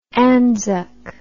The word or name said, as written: ANZUK